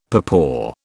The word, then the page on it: papaw: British English